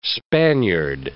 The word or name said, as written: Spaniard